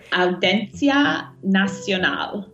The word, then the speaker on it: Audiencia Nacional: Am I saying that right? – Almost. You're getting better. Audiencia Nacional. – Audiencia Nacional, where the case against Manuel is being investigated.